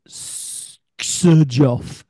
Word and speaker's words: Xerjoff: It's a collaboration between Duran Duran and S Sir Joff, we call it, which is spelt XERJOFF.